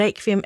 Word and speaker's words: requiem: Han er også en meget sprogkyndig mand, og han forklarer os, at den danske udtale af requiem er noget, man har til fælles med andre germanske sprog. Hvorimod man i Italien for eksempel vil udtale ordet med rulle-r og så det her ku.